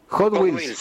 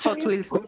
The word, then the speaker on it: Hot Wheels: Hot Wheels. – Hot Wheels. ¿Cuánto están? – Hola, buen día. Sí, buen día. – Todo tipo de pistas, autitos de Hot Wheels.